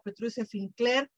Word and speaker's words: Petrucia Finkler: Está con nosotros mi querido Elhoim Leafar y está con nosotros mi querida Petrucia Finkler.